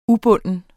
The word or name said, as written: ubunden